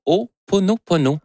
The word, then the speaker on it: Ho'oponopono: Non, c'est une philosophie hawaïenne de, je vais le prononcer comme il faut, de Ho'oponopono, le Ho'oponopono, qui est une philosophie ancestrale qui sert à régler les conflits, à lâcher prise, à modérer nos attentes et à rétablir sa paix intérieure. Donc, le Ho'oponopono, Ho'oponopono.